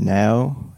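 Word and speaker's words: NAO: I believe she pronounces her name now. It's N A O. Okay. – That's what I was going to guess. Yeah. Now. – Now? Now? Something like that.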